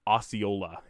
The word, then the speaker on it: Osceola: Osceola is the pronunciation.